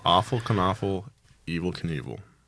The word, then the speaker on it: Knievel: Knievel. That's very important that you pronounce it.